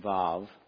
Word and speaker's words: Vav: It is spelled, I'm saying Vav, and sometimes you'll see it written in these other ways, but I believe it's pronounced Vav.